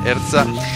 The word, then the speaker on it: HRSTA: Y enseguida vamos a escuchar al grupo excelente Erza, que tiene una pronunciación muy difícil, que se escribe H-R-S-T-A. Entonces es como, lo pronuncian como quieren. Yo lo pronuncio Erza.